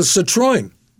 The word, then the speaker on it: Citroën: And he did the same thing years later in Europe with a Citroën. – Oh. – Is that how you pronounce it? – Yeah, that's right. The French model. Citroën.